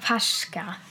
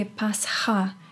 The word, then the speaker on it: Pascha: I said Pascha. – Pascha, but it's actually Pascha.